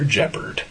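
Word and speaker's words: Jepperd: Is saved by Mr. Jeopard. – Rhymes with leopard.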